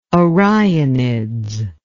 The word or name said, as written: orionids